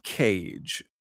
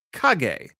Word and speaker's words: Kage: I believe this is pronounced cage. I look at it, and at first I think Kage, because it's spelled with a K. It's K-A-G-E. But then I think about what it's doing, and no, I'm sure it's supposed to be pronounced cage.